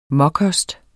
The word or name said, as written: mokost